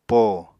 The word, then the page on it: po: Apatani